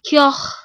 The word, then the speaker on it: Keogh: K-E-O-G-H apostrophe S. – All right. How to pronounce it. Let's hear what they tell us. – Keogh, Irish. Keogh. – So it's Keogh.